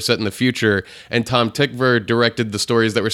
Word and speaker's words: Tom Tykwer: Tom Tykwer, as it is pronounced, even though it's spelled T-Y-K-W-E-R. – Right, Tykwer.